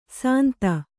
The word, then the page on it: ಸಾಂತ: Kannada